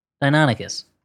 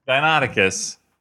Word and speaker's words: Deinonychus: Deinonychus. – Deinonychus all the way.